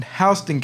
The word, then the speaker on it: Houston: Our county that we live in Georgia, is spelled Houston. So everybody who comes here that's not from here say, oh, yeah, we're in Houston County. – Houston. – No. You're in Houston County.